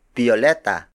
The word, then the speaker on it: biyoleta: Tagalog speakers also use the word biyoleta, biyoleta, biyoleta.